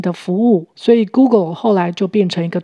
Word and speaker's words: Google: 请留意 Google 这个字的发音，第二个音节 G L E L 舌头要稍微抬高。 Google，Google，Rules Google，规则的复数形，在这里就是指麻将的游戏规则。